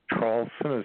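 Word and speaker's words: Traulsen: Yes, my name is Hugh, H-U-G-H. The last name, Trawlson, is spelled T like Tom, R-A-U-L, S like Sam, E like Edward, and like Nancy, and you can just put that on YouTube or Google it.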